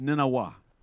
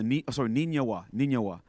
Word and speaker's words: Nineveh: That word Nineveh was originally pronounced Ninawah. It comes in the cuneiform in the way that they put it together. Ninawah.